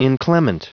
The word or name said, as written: inclement